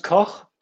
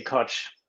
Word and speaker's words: Koch: So it's it's German and uh, it's it's pronounced Koch, but uh, I, I, I, say Koch.